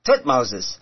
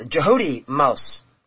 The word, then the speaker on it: Thutmose: And we got to come up with Thutmose. And the actual pronunciation a lot of Egyptologists use is Jehudi, the first part of that, Jehudi Mos. And so that's a better pronunciation than Thutmose, is Jehudi Mos.